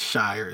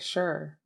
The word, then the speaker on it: shire: I said Shire. Is Shire the English way to say it? – I think sure is the right way to say it.